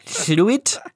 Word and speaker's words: Llwyd: I received an email uh, from fluid, I'm going to say fluid because it's the it's the Welsh thing with two L's, and I think it's fluid, but that could be it's spelled L L W Y D.